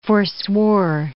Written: forswore

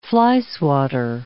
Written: flyswatter

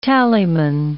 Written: tallyman

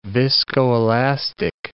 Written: viscoelastic

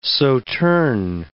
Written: sauternes

